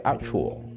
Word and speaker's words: actual: Se escribe actual, pero se pronuncia diferente. Esta vez la T la vamos a pronunciar como una CH. Sería actual, actual, actual.